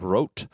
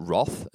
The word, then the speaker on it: Roth: My advice for, and I did this, I've got some personal experience with this, training for Ironman Rote, or Roth, as everyone calls it here in Australia.